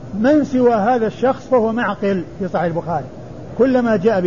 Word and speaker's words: معقل: من سواه في صحيح البخاري بهذه الصورة فهو معقل بالعين والقاف بالعين الساكنة والقاف المكسورة. ومغفل ومعقل رسمهما واحد يتفقان في الرسم ويختلفان في النطق يختلفان في الشكل والنقط ويتفقان في الرسم والصورة.